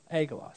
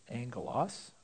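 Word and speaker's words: angelos (ἄγγελος): Now, whenever you have a double gamma in Greek, you actually pronounce it ng. Okay? So let's write it the way it would be pronounced angelos.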